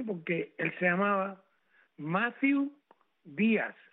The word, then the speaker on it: Díaz: pero es un apellido castellano que se llama Díaz. Tiene acento en la I, Díaz, y Z al último.